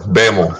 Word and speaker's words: Bammel: Bammel.